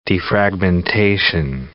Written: defragmentation

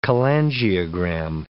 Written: cholangiogram

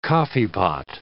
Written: coffeepot

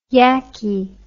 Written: yakky

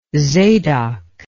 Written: zadok

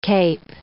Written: cape

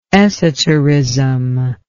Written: esoterist

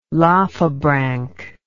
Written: lophobranch

